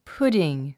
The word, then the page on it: pudding: púdiŋ; プディン